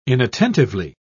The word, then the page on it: inattentively: British English